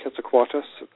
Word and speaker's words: Quetzalcoatlus: Because, you know, I'm thinking about the size, and I know that I think it's pronounced Quetzalcoatlus. I can't pronounce it. – Quetzalcoatlus. – Yes, thank you.